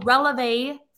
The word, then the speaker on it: Relevé: And so the company is called Releve Financial Group. – Releve. How do you spell that? – R-E-L-E-V-E.